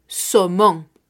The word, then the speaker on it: saumon: En francés decimos le saumon el e, una vez más, artículo masculino singular, y salmón que se escribe S-A-U-M-O-N, somón.